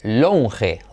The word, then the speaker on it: lounge: Pero curioso que se pronuncia lounge. El anterior era lounge y este es lounge, lounge, lounge. Letra clave L, pero se escribe OU y se dice AU.